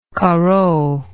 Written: Corot

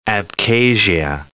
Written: Abkhazia

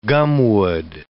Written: gumwood

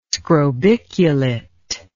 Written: scrobiculate

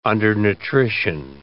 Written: undernutrition

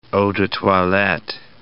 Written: eau de toilette

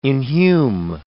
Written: inhume

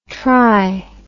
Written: tri

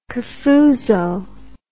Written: cafuso

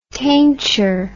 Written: tainture

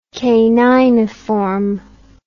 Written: caniniform

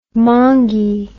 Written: mongy

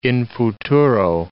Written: in futuro

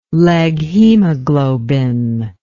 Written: leghemoglobin

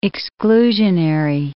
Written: exclusionary

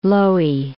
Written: Loewi

